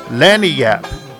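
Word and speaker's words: lagniappe: Lonnie Up. They pronounce it Lonnie Up. It is Spanish, so they said.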